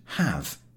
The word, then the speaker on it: have: I once heard an English lady explaining to a Frenchman how to pronounce the word have. – You will pronounce it, said the lady reproachfully, as if it was spelt H-A-V. It isn't. There is an E at the end.